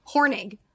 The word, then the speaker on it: Hornung: For his part, though, Carter was a finalist for the 2020 Paul Hornung. How do I say that? – Paul Hornung? – Hornig.